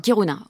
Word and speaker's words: Kiruna: Alors, il y a un deuxième long qui suivra en 2013, Rendez-vous à Kiruna, ça se prononce Kiruna ? – Kiruna, oui.